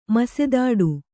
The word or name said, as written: ಮಸೆದಾಡು